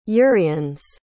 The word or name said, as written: uriens